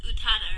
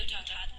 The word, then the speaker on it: Utada: Wait, you say Utada Hikaru? – Yeah. – She's amazing! – I see her name wrong all the time! – Oh my goodness, she did music for Kingdom Hearts and it's great. – It's Utada, right? – Yeah, Utada.